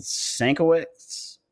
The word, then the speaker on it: Sienkiewicz: By Jeff Lemire, pencils by Dennis Cohen, inks by Bill Sienkiewicz. I don't know how to say Sienkiewicz. – Sienkiewicz. I'm sorry.